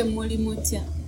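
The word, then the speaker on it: Muli mutya: If it's a crowd, you can say Molimotia. Molimotia? – Yeah, for the people more than one.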